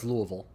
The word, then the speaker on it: Louisville: It's Louisville. – Louisville. – I like to always try to pronounce things the way people, locals do because, you know, it's a vestige of when I was younger and all I cared about was looking smarter than everyone else.